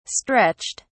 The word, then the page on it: stretched: British English